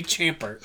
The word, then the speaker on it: Chabert: It's spelled Chabert, so I'm going to say Chabert.